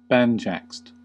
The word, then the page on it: banjaxed: American English